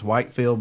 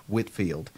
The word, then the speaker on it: Whitfield: I know if you've never seen this before, it looks like it's Whitefield, but it's actually pronounced Whitfield.